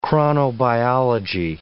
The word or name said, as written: chronobiology